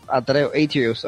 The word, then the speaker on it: Atreus: ¿Lo dije bien o lo dije? ¿O lo dije mal? Atreus. Yo lo leo Atreus. – Atreus, ok, Atreus. – Ajá. Oh, no lo dije tan mal.